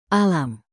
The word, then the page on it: alam: indonesian